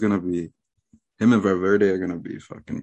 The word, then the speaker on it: Valverde: Valverde. I'm saying his name right. – Valverde.